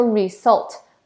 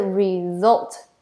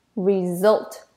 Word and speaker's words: result: 要记得这个 s 呢不是发 s 的音，而是 z 的音。我们不会说 result，而是 result， result。